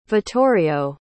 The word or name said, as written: vittorio